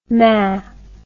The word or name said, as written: Mae